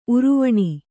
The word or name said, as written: ಉರುವಣಿ